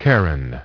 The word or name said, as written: Charon